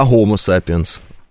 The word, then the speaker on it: Homo sapiens: Тогда Homo sapiens, а не Homo sapiens или Homo sapiens. Homo. Какой Homo? Homo sapiens. Вот так вот надо правильно произносить. Как будто бы вы украинец.